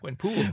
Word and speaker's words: Gwenpool: Gwenpool, not pole.